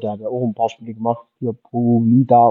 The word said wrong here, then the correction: Polivota: oder wie ist der, wie wird das Spiel ausgesprochen? – Maximilian Polivota. – Genau. Polivota.